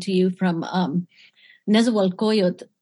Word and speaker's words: Nezahualcóyotl: Okay, Nezahualcóyotl. I'll send it to you. Nezahualcóyotl.